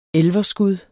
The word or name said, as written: elverskud